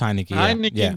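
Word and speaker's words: Heinicke: It's Heineke.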